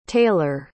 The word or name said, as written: taylor